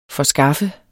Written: forskaffe

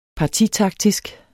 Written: partitaktisk

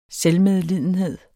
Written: SELVMEDLIDENHED

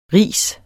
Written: ris

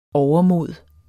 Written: overmod

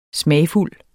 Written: smagfuld

